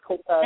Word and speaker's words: Copacz: Yep, Dave Copaz, C-O-P-A-C-Z, the C is silent, yeah. – So it's Copaz.